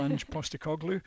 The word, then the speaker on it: Ange Postecoglou: I was impressed by the way you pronounced that. It's a fair old mouthful, Ange Postecoglou.